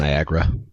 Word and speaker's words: Niagara: Am I saying that right? Pretty sure like that's it. – Niagara. – Oh, okay, Ni- there's just Niagara.